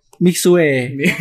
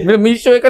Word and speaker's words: Mixue: Lu lu pertama pas apa nemu tempat es krim lu bacanya gimana? – Mixue. Kayak gitu. Terus Felika. Felika. Dia jadi Felika. Bukan kayak gitu. Yang bener kayak gini. Gimana? – Mishwe.